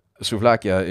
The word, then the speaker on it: souvlakia: Souvlakia is how you say the plural of souvlaki in Greek.